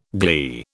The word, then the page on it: gley: British English